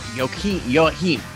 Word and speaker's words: Joachim: Joachim, correct? Joachim. – Yes, I just want to make sure I get this right. Joachim, I need Joachim. I'll probably pronounce it that way. I can't quite get the German pronunciation, despite being German heritage myself.